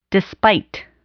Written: despite

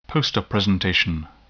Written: poster presentation

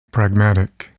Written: pragmatic

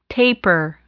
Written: taper

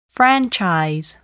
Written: franchise

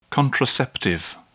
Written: contraceptive

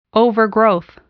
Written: overgrowth

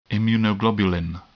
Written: immunoglobulin

